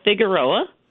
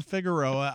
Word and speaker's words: Figueroa: This week, we focus on the Independent Living Center of the Hudson Valley and welcome their executive director, Denise Figura. And Denise, am I saying your name correctly? – It's Figueroa.